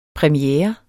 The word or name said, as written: premiere